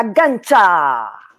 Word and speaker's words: Gancia: Então, Bárbara Gancha! Alô, como vamos? Eu gosto quando pronuncia meu nome certo. E aí da Itália tem que pronunciar direito mesmo, porque eu sou italiana.